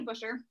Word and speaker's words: Buschur: So I am Maddy Buschur. Close. It's Bush like the beer or the plant, and then just you are.